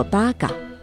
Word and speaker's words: 马鹿: 马路在日语中读作八嘎，这个词从南北朝时一直沿用到了现在。